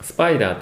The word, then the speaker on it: SPDR: スパイダーと読むん